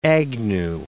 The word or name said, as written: Agnew